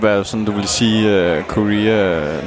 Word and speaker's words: Corea: Det er faktisk mere det, han hedder, har jeg hørt om, når han selv udtaler det, fordi det er sådan italiensk Corea. Chick Corea. Frem for det sådan her, vi kalder ham Corea.